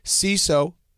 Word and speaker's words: Seeso: CISO, it's spelled S-E-E-S-O, is the new ad-free streaming service bringing you hilarious original series, weeks of stand-up specials, and more.